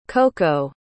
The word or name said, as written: koko